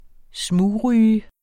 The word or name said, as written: smugryge